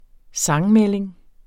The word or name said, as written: sansmelding